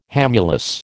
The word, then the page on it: hamulus: American English